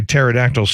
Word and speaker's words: pterodactyl: Can someone please explain why pterodactyl starts with a P? – Yeah, I've actually always wondered that as well. – Yeah, the whole silent P thing never makes any sense to me.